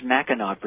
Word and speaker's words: Mackinac: pronounced Mackinac Bridge, actually.